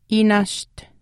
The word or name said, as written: iinaasht